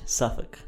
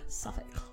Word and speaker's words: Suffolk: Suffolk. – Suffolk. – I think I've heard people say that before. It's, yeah, we're in Suffolk. – S-U-F-F-O-L-K. – I think they make the Suffolk L silent.